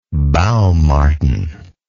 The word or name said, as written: baum marten